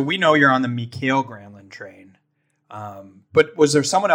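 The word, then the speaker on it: Mikael Granlund: Was there, so you're all on the Michael Granlund train, but was there someone else that, what? Isn't it Mikhail Granlund? – Sure. Yes. Sorry.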